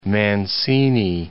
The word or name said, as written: Mancini